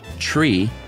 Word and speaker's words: tree: Three is pronounced tree, to not be confused with the antiquated prefix sri, S-R-I.